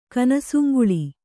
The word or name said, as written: ಕನಸುಂಗುಳಿ